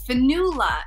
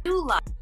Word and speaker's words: Fionnghuala: My real name is Finula. It's an Irish name, and it's spelled F-I-O-N-N-G-H-U-A-L-A. Finula.